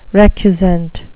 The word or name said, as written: recusant